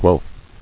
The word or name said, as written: Guelf